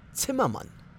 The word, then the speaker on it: Zimmermann: And number one is going to be Zimmerman. Now, guys, this is not Zimmerman, okay? The Z is a tet, so it's going to be Zimmermann. Zimmermann. Say with me, Zimmermann.